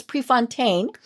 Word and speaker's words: Prefontaine: And I mispronounced your last name a little bit. It's Prefontaine.